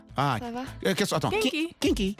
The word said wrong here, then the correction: genki: Non, kinky, Janai. Genki. – Ah, genki.